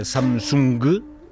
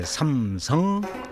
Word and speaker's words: Samsung: Não é Samsung, mas é, em coreano é Samsung, Sam é três, Sam é estrela.